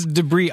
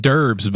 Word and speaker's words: debris: Yes, it's debris all over the place. – Now, yes. – I have said derbs before. It's debris.